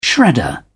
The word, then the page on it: shredder: British English